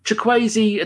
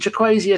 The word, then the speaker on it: Chukwueze: Chukwueze attacks, thank you very much for the pronunciation. Chukwueze attacks down our left side, obviously.